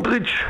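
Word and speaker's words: Modrić: Modric.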